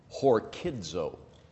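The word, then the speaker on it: Horkidzo (ὁρκίζω): That word charge, that if you underline that word there in your Bible, the word charge is coming from the Greek verb horizo. Horizo. Oh, excuse me, I'm saying that wrong. Horkidzo.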